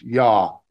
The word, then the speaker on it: Yah: It uses just the first two letters, Y, and it's often spelled or pronounced Yah, Y-A-H.